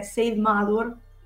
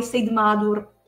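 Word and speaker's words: Seiðmaður: Dunque, no, in realtà la pronuncia è abbastanza simile a come si vede scritto, perché Said Madur, se proprio si vuole appunto pronunciarlo alla nordica, si pronuncia la D tagliata, quindi somiglia al the del the inglese, molto banalmente, esatto. Però anche Said Madur all'italiana va benissimo e si capisce lo stesso.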